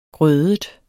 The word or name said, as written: grødet